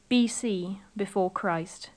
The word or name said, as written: before christ